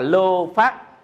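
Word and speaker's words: lobha: À, tham á là ông lô phát. Cái này là chữ tham nè, mình đọc là lô phát.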